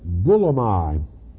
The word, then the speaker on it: boulomai: And the word is B-O-U-L-O-M-A-I. It's pronounced bulomai.